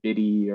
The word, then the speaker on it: BiDi: And uh, as I understand it, there's quite a lot of contention on how you actually pronounce this, whether it's bidi or biddy or something like that. So, so take your, your choice and how you'd like to pronounce this algorithm. But uh, I'll call it BiDi.